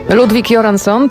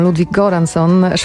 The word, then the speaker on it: Ludwig Göransson: Ludwig Göransson, trzymam się tej szwedzkiej wersji wymowy, choć niektórzy mówią Ludwig Göransson,